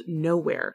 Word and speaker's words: Knowhere: Anyway, Blade Savick is a firefighter and paramedic in the small town on the West Washington coast called Nowhere. That is spelled as K-N-O-W-H-E-R-E.